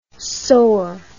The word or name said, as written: saur